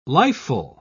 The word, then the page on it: lifeful: British English